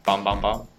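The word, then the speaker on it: 봄봄봄: Bom bom bom. – Yes, because, you know, that's how it's pronounced in Korean, sort of.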